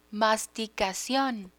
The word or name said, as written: masticacion